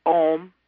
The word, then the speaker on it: Ogham: It was written in Ogham, O-G-H-A-M, which some pronounce Ohm, but we have said Ogham and pronounced the hard G.